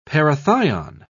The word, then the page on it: parathion: British English